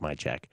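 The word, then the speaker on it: Majchrzak: It's actually spelled Majchurizak phonetically, but it's pronounced Majchek.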